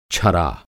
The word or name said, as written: छरा